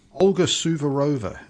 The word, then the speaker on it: Olga Suvorova: Olga Suvorova, is that how I pronounce it? – That's right, yeah.